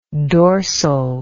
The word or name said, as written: dorsality